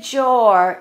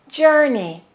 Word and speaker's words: journey: Don't say jor, it's jur, journey, journey.